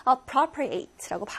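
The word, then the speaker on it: appropriate: 보통은 형용사로 적절한이라고만 기억을 하시는데 동사로 쓰이면 혼자 독차지하다 appropriate라고 발음을 합니다.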